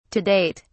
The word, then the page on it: to date: British English